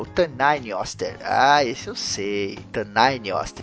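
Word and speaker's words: Tanngnjóstr: Porque a gente fez mais ACC, lá um audiodrama, né, entre o Hércules e o Thor, e eu tive que falar Tanainioster umas 20 vezes. Então eu aprendi. Mas o nome do Tanainioster se escreve assim, ó, Tanginjoster.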